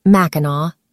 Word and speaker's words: Mackinac: Joan and Frank Nephew opened the first Joanne's Fudge store on Mackinac, pronounced Mackinac, Island.